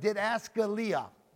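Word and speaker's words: didaskalia (διδασκαλία): This word doctrine comes from the Greek word didaskalia. Didaskalia. It's spelled D-I-D-A-S-K-A-L-I-A, didaskalia.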